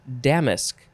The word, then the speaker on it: damask: It is Damask. I think that is how you pronounce it. Damask. D-A-M-A-S-K. Noun from the 14th century. The emphasis is definitely on the first syllable.